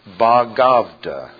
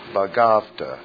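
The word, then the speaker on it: Bhagavad: We should add, by the way, that probably the most sacred and read book among the Hindus is the, it's spelled B-H, and it's pronounced Bhagavata. Bhagavata. Gita, G-I-T-A.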